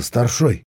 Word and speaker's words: старшой: «О чем задумался, старшой?» С ударением на последний слог осведомился Левчук.